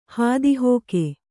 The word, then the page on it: ಹೊತ್ತುಹೋಗು: kannada; hottu hōgu